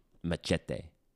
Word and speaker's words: Machete: I've seen none of the Machete movies. – I recall the first one. I think you're saying it wrong. – Machete. Machete Kills. – There we go. – There you go.